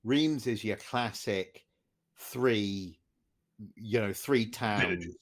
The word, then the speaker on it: Reims: up at Reims, or Reims as we would pronounce it, I suppose, but the French wouldn't. Where they were racing their sports cars in the 50s and stuff.